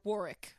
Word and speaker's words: Warwick: No, I'm just saying Warwick is how Warwick Davis pronounces his name.